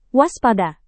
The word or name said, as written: waspada